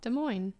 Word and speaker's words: Des Moines: How do you pronounce that? – Des Moines. – Okay. – Yeah. Good.